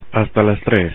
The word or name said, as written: hasta las 3:00